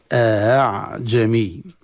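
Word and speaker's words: أأعجمي: يقرأ بتسهيل الهمزة الثانية، يعني هكذا: أعجمي أعجمي.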